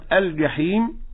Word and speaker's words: الجحيم: الصواب أنه من وسط اللسان هكذا الجيم، هكذا الجحيم.